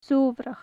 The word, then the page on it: sobhrach: gaelic